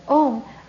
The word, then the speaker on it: Om: Om is consists of three syllables, A-U-Ma.